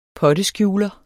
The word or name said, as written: potteskjuler